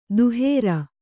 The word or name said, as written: ದಹರ